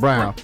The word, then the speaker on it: Braun: It looked like Braun, but it's pronounced Brown.